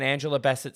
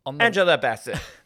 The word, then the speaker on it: Bassett: We could get an Angela Bassett snubbage. Sorry, I said that wrong. Angela Bassett.